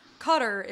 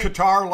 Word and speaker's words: Qatar: We talked about this on the Levitard show too because Amin Al-Hassan he speaks Arabic and he was saying that Qatar is the closest to how you pronounce it in Arabic. – Okay. – And then I read this New York Times article, and basically the ending was like, well, Qatar's probably the most incorrect.